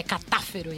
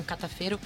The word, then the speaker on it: Catafero: E a gente acabou de ouvir aqui, aliás, corrigindo a minha pronúncia, é catáfero e não catafeiro, do, com a música Tanatolátria do álbum Life de 2012.